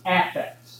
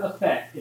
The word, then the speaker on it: affect: In the sense we're using it in here, and that we use it in communication, and that they use it in psychology, that A is a short A, so it's affect. It's never effect, it's affect.